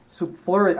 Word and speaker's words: superfluous: For it is superfluous. How do you say that word? – Superfluous.